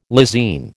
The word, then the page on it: lysine: American English